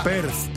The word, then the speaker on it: Perth: no, Pez, Pez, me parece que se pronuncia así, es la... Perth, Perth, ah, ya sé, en Australia.